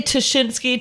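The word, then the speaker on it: Ptaszynski: Well, I say Tashinsky. Dan Schreiber, who hosts our podcast, says Jasinski, which is a mispronunciation.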